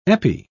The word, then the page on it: epi: British English